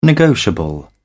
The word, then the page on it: negotiable: British English